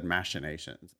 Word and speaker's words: machinations: But even if I hadn't followed that, the machinations, is it really machinations with a hard K in there? I always would have said machinations or something.